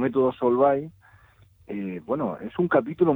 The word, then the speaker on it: Solvay: Y, has elegido como tema eh, el método Solvay o Solvay. ¿Cómo ¿Cómo lo, cómo lo pronuncias? – Pues Solvay está bien, Solvay.